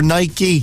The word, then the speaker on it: Nike: It is Nike. Or Nike, depending on your pronunciation.